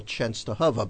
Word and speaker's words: Częstochowa: You know, being 100% Polish, it's kind of embarrassing that I wasn't able to spell Częstochowa. But let me spell that for you now so you can go to the website. – But pronunciation is very good.